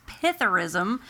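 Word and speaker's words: psithurism: Which comes, it's P-S-I-T-H-U-R-I-S-M. – I'm sure the P isn't. – The S is silent.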